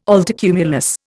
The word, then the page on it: altocumulus: Indian